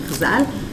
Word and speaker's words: Chazal: And you pronounce it shortly as Chazal.